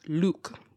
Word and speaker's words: lewk: including yeet, great choice, pumpkin spice, meh, MacGyver and Luke, spelled L E W K, pronounced Luke, not look.